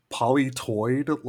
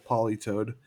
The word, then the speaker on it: Politoed: When I was younger, I always pronounced it polytoid, like hemorrhoid. – Now I'm back in. – Misty had one. We were in our dark moments for Pokemon watching when she had a Politoed.